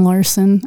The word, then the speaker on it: Larsen: And Jamie is spelled J-A-M-I. No E on the end. – No, the E's in Larson, L-A-R-S-E-N, not the O.